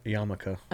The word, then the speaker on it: yarmulke: Dog, it's a yarmulk. Homie, no cap. Oh my God, he said no cap. – Yarmulke, I think. – What's it? Yarmulke? – Yarmulke.